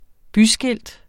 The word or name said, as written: byskilt